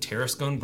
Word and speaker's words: Tarascon: And you can spell it phonetically and you'll get it right. Tarascon Brothers.